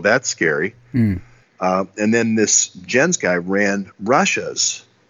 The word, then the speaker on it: Jens: I don't know how you say it, J-E-N-S, Jens. Mm-hmm. It's like a Scandinavian male name, Jens. – Oh, Jens, yes. – Jens, okay, Jens. Kuhn, K-U-H-N. K-U-H-N, yeah, Kuhn.